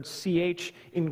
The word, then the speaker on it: chi: In English, that's rendered ch. In Greek, that's the key. Side note, have you ever seen those XPO logistics trucks? In Greek, those letters are chi, and the P actually is pronounced rho.